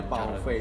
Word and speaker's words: Baofei: ya Pauve bacanya Pauve cara di Google tapi uh, Baofe gitu Baofe, ya? B A O F E I.